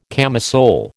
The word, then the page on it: camisole: American English